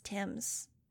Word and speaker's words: Thames: It's pronounced Tim's, Total said, licking one paw.